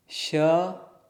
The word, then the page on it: ś: sanskrit